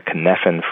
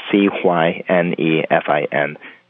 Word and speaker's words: Cynefin: That framework is called the Cynefin framework, and it's spelled C Y N E F I N for those who can't speak Welsh.